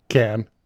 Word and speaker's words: Cannes: It's a can. – Cannes. When that thing was rolled out at Cannes. Sorry, yes, mispronunciation.